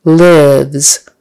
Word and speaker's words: live: Lives. Short I, lives, or live, rhymes with give.